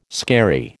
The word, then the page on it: skerry: American English